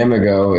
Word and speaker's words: imago: Is that how you say it, in imago? – Yeah, imago. It's imago.